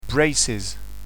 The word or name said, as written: braces